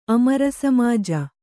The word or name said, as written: ಅಮರಸಮಾಜ